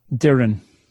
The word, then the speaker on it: Doireann: Doireann.